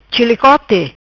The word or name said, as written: Chilicote